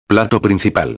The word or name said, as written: plato principal